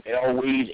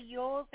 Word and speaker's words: Elwes: I don't know how you pronounce the last name. Elwes, E L W E S. Yeah, um, Cary. He Cary Yules or something like that.